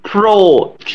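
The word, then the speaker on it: Protea: Pro T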